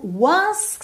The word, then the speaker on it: once: Só mesmo once que você tem que prestar atenção que o E final não é pronunciado e ele tá pintadinho de cinza aí para não deixar você esquecer.